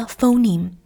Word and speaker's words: phoneme: 我们之前已经讲过了，英语里管音素叫 phoneme， P-H-O-N-E-M-E phoneme phoneme 音素就是组成一个读音的最小单位。那么 phoneme 这个单词里面有两个 syllable，两个音节 Phoneme，通常每一个音节是由一个辅音音素和一个元音音素构成的。 For example，比如说 phoneme 这个单词，